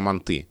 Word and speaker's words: манты: И вот мы стали выяснять, кто откуда, и выяснил, что даже в разных областях Киргизии – это слово произносят по-разному. Где-то манты, где-то манты.